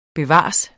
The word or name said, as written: bevares